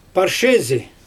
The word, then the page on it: parshezi: Xyzyl